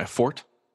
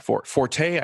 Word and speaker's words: forte: which I will admit is not necessarily my forte. Yes, my forte, I believe, from what I understand, I believe, is not the correct way to pronounce it. It is fort.